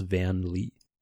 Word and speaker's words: Van Leeuwen: How would you pronounce Van Leeuwen? – Just like you did.